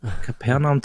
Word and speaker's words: Kapernaum: Ich spreche es wie in meinem Lateinunterricht aus. Kapernaum, Capernaum. – Ja, klingt richtig.